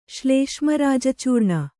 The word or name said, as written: ಶ್ಲೇಷ್ಮರಾಜಚೂರ್ಣ